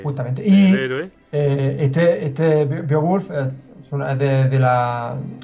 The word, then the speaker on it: Beowulf: Beowulf. – Beowulf.